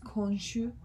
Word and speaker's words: Khonshu: It's actually pronounced Khonshu, but like with more like an Arabic. – Yeah. – It's Egyptian. It's an Egyptian word.